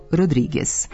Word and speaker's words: Rodriguez: Niti je često špansko prezime Rodriguez, nego Rodriguez.